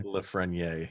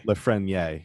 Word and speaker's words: Lafrenière: I believe his name is Alex Lefrenet, if I'm pronouncing it right? Lafrenière. – Lafrenière.